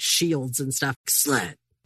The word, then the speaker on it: SLED: Yeah, you have to say it like SWAT. You're basically saying SWAT, but you're saying SLED instead.